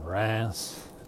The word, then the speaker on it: Reims: So they pronounce it Reims. Reims. Which, to an American's ear, English ear, Reims, sounds like it would be R-E, or maybe R-E-A, N-C-E, Reims, R-E-A-N-C-E, Reims. But it's actually spelled R-E-I-M-S, which I would think would be like rhymes, but it's Reims, because that's just the way the French do it.